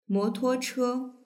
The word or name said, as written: motuoche